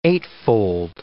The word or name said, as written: eightfold